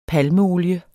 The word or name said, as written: palmeolie